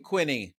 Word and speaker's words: Quiney: I'm Jason Feltman, and today we have an incredibly exciting guest, Lord Adam Quiney. Did I pronounce that correct? – Quiney. It rhymes with whiny.